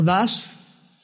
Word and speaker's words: Doss: Desmond Doss, o Das, como se pronuncia.